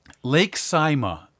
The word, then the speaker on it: Saimaa: My last one, Lake Saimaa in Finland.